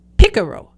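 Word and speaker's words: pícaro: But in fact, it comes from a Spanish word that rhymes with Figaro. It's picaro.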